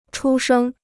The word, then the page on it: 出生: chū shēng